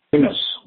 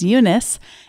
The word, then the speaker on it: Yunus: Uh, the title is Building Social Business by Mohammed uh, Yunus, Y-U-N-U-S, I believe it's pronounced, Mohammed Yunus, and uh, really enjoying that.